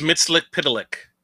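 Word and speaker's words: Mxyzptlk: It's Mitzlik Pidalik.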